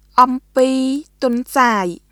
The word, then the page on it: អំពីទន្សាយ: オンピー・トンサーイ; ʔɔmpiː tʷɔ̀nsaːi